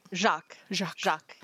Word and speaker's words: Jacques: So shout out to our Northern friends, Elliot Jacques. Am I saying that right? Because it's a French name, right? – Jacques. Yeah.